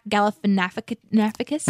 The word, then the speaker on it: Galifianakis: Zach Galifianakis. It's like Snuffleupagus, right? – Yes, no, yeah, exactly correct.